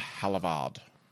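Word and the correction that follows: Havilland: I believe I'm pronouncing that incorrectly. – Haviland, probably. – Haviland, probably. – Not Hadavar. I didn't know what she did the first time. – Haviland. Haviland.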